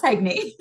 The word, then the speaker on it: Heagney: You did, yes, Heagney.